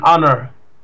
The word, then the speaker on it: honor: In other words, like an honor. Don't you? It's not a honor. An honor when it's like a silent H.